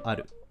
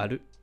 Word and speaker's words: ある: 標準語だと「ある」うん、あ」の方にアクセントがつくけど、うん、えー、関西弁だと「る」の方にアクセントがついて「えー、ある」になる